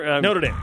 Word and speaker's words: Notre Dame: Is that how you say it? – Notre Dame. – Notre Dame.